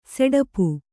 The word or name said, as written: ಸಡಾಪು